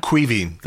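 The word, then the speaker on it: Caoimhín: I might mangle this pronunciation, but is it Kevin Kelleher? – Queeving. Queeving Kelleher. – Queeving. Yeah. I was close.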